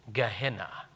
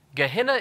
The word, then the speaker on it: Gehenna: Gehenna is not a translation. It is a transliteration, meaning it is spelled kind of like the way the original word sounds. It is a transliteration of the Hebrew Gehenom.